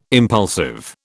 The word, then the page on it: impulsive: British English